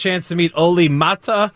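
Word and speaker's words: Määttä: It's you got to get scared in the middle of the last name to make that work. Mete. – Oli Mete.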